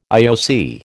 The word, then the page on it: IOC: American English